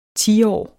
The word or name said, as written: tiårs